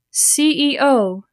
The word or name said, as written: CEO